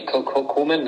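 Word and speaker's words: Koeman: Wieder 3 zu 0, ich glaube, Koman, oder nee, Komen, ne? Komen wird ausgesprochen.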